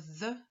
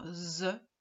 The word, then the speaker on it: the: J'insiste sur le the. Souvent, j'entends the. Le th en anglais se prononce th. La langue, le bout de la langue doit être sous les dents devant.